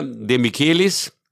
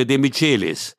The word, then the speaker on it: De Michelis: Bayern-Spieler De Michelis oder De Michelis. Beides richtig übrigens. Ne? Mhm. De Michelis ist die spanische Aussprache und De Michelis ist die Uraussprache des Wortstammes.